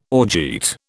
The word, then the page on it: orgeat: British English